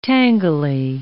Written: tangly

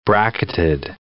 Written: bracketed

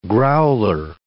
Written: growler

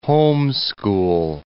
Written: homeschool

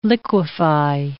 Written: liquefy

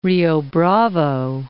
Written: Rio Bravo

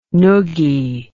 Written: nuggie

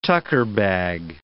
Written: tucker-bag

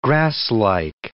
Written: grasslike

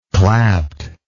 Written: clapt

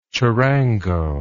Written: charango

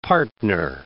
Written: partner

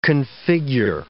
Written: configure

